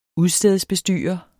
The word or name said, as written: udstedsbestyrer